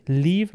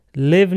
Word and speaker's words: leave: Leave به معنی ترک کردن. L-E-A-V-E. تلفظش رو دقت بکنید. Leave. Live نیست. Live. ایه کوتاه نیست. ایه بلنده. Leave.